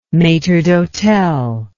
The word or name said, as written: maitre d'hotel